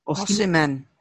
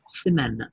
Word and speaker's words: Osimhen: Osimhen. – Osimhen.